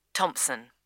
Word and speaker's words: Thomson: Janet Thomson. – Is that Thompson spelled with a P? – No, it's T H O M S O N.